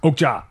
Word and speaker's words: Okja: Okja.